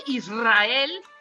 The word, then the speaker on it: Israel: Oye, entonces, ¿por qué Israel, que todo mundo dice Israel, no se escribe con doble R y se dice? – Porque no está entre dos vocales, está entre una S y una A.